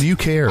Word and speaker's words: Euchre: I'm trying to actually remember how the game Euchre works. – What game? – Never mind. – It's called You Care.